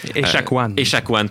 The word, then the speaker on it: Echaquan: Exactement, parce que ça aurait contribué à la mort de Joyce Etchemin. – Echaquan, pardon.